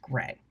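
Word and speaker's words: greige: And it is pronounced gray.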